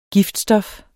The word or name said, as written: giftstof